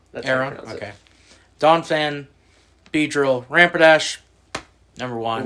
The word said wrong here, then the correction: Rapidash: Rapidash. Rampidash. Rapidash. You're saying ramp. Rapidash.